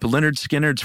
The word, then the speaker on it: Lynyrd Skynyrd: Next up, Lynyrd Skynyrd's first record, pronounced Lynyrd Skynyrd.